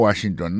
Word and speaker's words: Washington: Si on a mis accent sur accent encore, on va voir que le mot Washington, l'accent tombé sur le premier syllabe là.